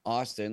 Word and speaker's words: Austin: It's my first name, Austin, like the capital of Texas, and then Mo, which is spelled M-O, and then .com.